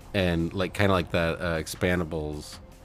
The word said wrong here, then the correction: Expendables: Expendables? – Did I say it wrong again? – Yes.